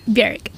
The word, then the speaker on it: Björk: Björk. – Yeah. – Rhymes with jerk.